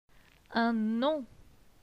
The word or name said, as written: Un nom